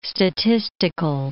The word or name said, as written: statistical